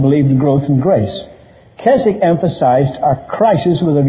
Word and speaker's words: Keswick: Sometimes they pronounce it Keswick, but it's Keswick.